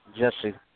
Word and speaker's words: Jussie: Jussie.